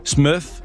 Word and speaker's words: SMRF: Der er en, der siger SMRF-regeringen udtales smøf, som på engelsk, der betyder smølf.